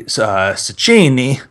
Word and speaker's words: Cecchini: I mean, I did a little bit of research, or rather my cousin Gene did, and they got this guy, Cecchini, down in the minor leagues. That's how you say it, for real, not like a moron.